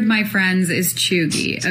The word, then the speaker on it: cheugy: It's a term called chuggy. – How do you spell it? – Chuggy is C-H-E-U-G-Y.